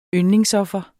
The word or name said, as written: YNDLINGSOFFER